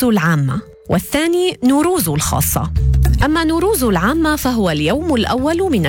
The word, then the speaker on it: نوروز: النيروز لفظة فارسية معربة، وتعني أول يوم من أيام السنة الشمسية عند الفرس، وتلفظ بالفارسية نوروز، وهي جمع لكلمتي نو وتعني جديد، وروز وتعني يوم، ليصبح المعنى يوم جديد.